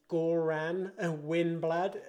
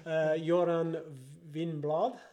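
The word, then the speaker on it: Göran Winblad: For those English speakers, it's Goran Winblad is how it would look for us people. Joran Winblad, is how you pronounce it.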